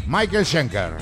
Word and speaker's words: Michael Schenker: A ver si lo digo bien. Michael Schenker. – Michael Schenker. – Muy bien, lo he dicho. – Lo has dicho casi perfecto.